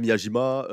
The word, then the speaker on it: Miyajima: Ouais, Miyajima.